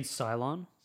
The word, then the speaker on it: Cylon: Did you also always think Cyclone, like, from Battlestar Galactica? But then you're like, that's the wrong religion. – You mean Cylon? – Cylon. Oh. Is that how you say it? – Yeah, Cylon.